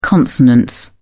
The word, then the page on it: consonance: British English